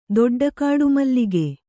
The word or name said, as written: ದೊಡ್ಡಕಾಡುಮಲ್ಲಿಗೆ